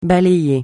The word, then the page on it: balayer: francais